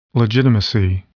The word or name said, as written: legitimacy